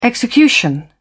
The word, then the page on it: execution: British English